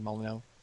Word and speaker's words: Molyneux: We had a story about how Molyneux said, is that how you say his name, Molyneux? – Yeah.